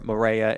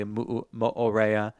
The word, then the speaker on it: Mo'orea: Well, I know with Hawaii or words, you're supposed to pronounce every single vowel. That's why it's Hawaii, or Morea is actually Mo'orea.